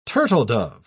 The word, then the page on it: turtledove: British English